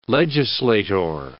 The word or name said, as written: legislator